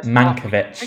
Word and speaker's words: Mankiewicz: Mankiewicz.